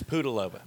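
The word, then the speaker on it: Pudilová: Is that how you pronounce it? Because I wasn't going to try. – It's Pudilova.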